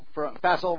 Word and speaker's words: Faisal: We're going to have a report from Pastor Faisal. Faisal rhymes with castle from Pakistan.